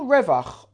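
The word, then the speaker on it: revach (רווח): If you spell revach, resh yud vav ches, and you take the yud out, It's spelled Resh Vav Ches. Revach, Resh Vav Ches, can be spelt, could be pronounced, I'm sorry, Ruach, Spirit.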